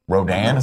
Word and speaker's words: Rodan: This the new one features, or supposedly features, Rodan, is that how you say it? – Yeah.